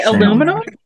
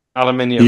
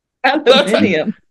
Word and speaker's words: aluminium: Aluminum? – Aluminium. – You said it really weird. – Aluminium. – That's how it's pronounced in this country. That's the word. The word is aluminium.